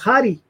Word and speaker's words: Jade: it's her name is pronounced Hari in Spanish. – Hari. – Yeah, and I was like, oh, that's funny. I was just like, yeah, you know, the J.